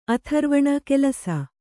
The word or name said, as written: ಆಥರ್ವಣ